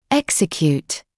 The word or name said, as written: execute